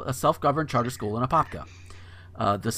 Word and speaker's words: Apopka: And this next one is out of Apopka. And that is how it's pronounced.